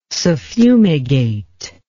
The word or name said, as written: suffumigate